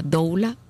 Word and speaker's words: doula: Nuestro tema del día de hoy, parto respetado y la doula o dula, como también se pronuncia, con nuestra invitada, la doctora Vivian Segarra, especialista en ginecología y obstetricia, y también es especialista en doula, hizo los estudios.